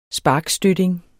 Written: sparkstøtting

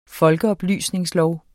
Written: folkeoplysningslov

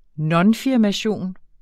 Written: nonfirmation

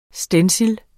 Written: stencil

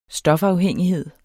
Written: stofafhængighed

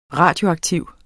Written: radioaktiv

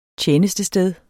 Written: TJENESTESTED

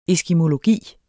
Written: eskimologi

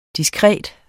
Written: diskret

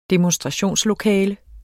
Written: demonstrationslokale

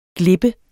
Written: glippe

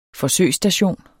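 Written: forsøgsstation

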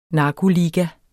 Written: narkoliga

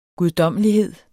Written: guddommelighed

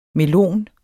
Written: melon

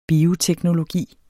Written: bioteknologi

